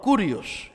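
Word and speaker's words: Kurios (κύριος): Se llama Señor Kurios. Así se escribe Señor en el griego. Claro que en el griego son diferentes, así se pronuncia.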